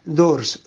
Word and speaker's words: Dores: Y eh, la playa se llama Dors, Dores se escribe,